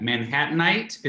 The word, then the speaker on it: Manhattanite: How do you say that? Manhattanite? Am I saying that right? – There you go. You got it.